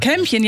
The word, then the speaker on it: Campion: Campion, ja, stimmt, nicht Compton, Campion, genau.